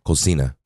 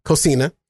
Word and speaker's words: cocina: How do you pronounce it again? – Cocina. – Cocina. – Spanish for kitchen.